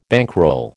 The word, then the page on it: bankroll: American English